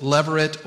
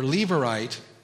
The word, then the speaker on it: levirate: In the laws of Israel, there was a provision for widows that was known as, you can either pronounce it, leveret or leverite marriage.